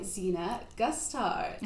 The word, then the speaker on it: Gusthart: And today we are joined by Xena Gusthart. Hi. Did I say your name right? – You said it perfectly. Yay. – Absolutely perfectly.